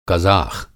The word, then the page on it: कज़ाख़: قازق